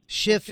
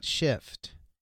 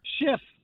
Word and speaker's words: Schiff: What I'm saying is Schiff did not have it. – Schiff. Okay? His name is Schiff, not Shift, Roger. – Schiff.